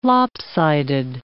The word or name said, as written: lopsided